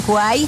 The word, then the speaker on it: Kwai: Para que la descarguen, se escribe K-W-A-I latina. – Ajá. – K-W-A-I latina. – Sí. – Se pronuncia Kwai.